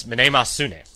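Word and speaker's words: Mnemosyne: That's pretty much it. It's Manemosune. Yeah, that's in Greek. – It sounds so Japanese. – It really does. – Doesn't that sound like Japanese, Jeremy? You're taking Japanese class, right? – It does, actually. – It really does. But, that is the correct Greek pronunciation, though. Manemosune.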